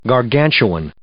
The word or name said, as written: gargantuan